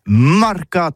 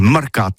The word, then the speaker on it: mercats: Eh bien, disons à tous, mais le mercat de l'Uni ou le mercat des rebelles, mais en gascogne toulousano, c'est prononcé sur les marcats, avec un A marquette. En gascogne toulousaine, bah, du côté, par exemple, de l'île des Baches, c'est l'île Jourdain, pour parler du marché, vous prononcez les marcats.